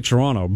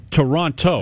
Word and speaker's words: Toronto: So it's spelled T-R-O-N-N-O. – It is spelled Toronto. – Toronto. – That's good. – Like Toronto is totally fine. Like you can do that. But if you're from Toronto, you usually don't pronounce the second T.